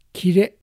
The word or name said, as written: Kire